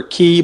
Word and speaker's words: Cay: So, and you know what, Greg, just to remind the folks that it's Deepwater Key, but it's spelled C-A-Y.